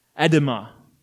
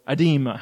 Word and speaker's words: edema: Now, dropsy is not a very familiar word probably to most of you, but another word might be, and that's the word edema, or edema, how you pronounce it.